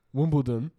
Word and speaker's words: Wimbledon: No, D. Not Wimbledon. – Yeah, there we go. – It's the same shit. – No, it's not Wimbledon.